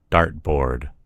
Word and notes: dart board: American English

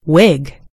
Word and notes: wig: American English

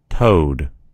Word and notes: toad: American English

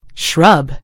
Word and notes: shrub: American English

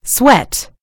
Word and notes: sweat: British English

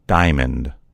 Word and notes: diamond: American English

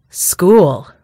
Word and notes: school: British English

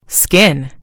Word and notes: skin: British English

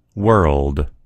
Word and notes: world: American English

